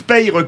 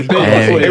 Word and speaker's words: Peille: Moi, je prononce Peille comme dans le Sud. – Ah, c'est Peille.